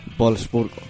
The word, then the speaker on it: Wolfsburgo: ¿cómo se dice, por favor? – Wolfsburgo.